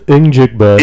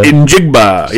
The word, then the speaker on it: Njigba: Jackson Smith Njigba. – Njigba, yes.